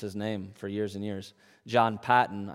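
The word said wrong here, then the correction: Paton: I always said, and now I know it's John Payton.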